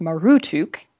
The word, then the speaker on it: Marduk: The name Marduk was probably pronounced Marutuk,